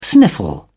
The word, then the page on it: sniffle: British English